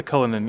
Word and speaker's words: Cullinan: It was a Cullinan. Is that how you say it? – The Cullinan, yeah.